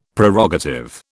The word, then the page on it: Prerogative: British English